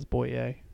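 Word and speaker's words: Bowyer: If you're from down south, it's Boyer. That's how they pronounce it.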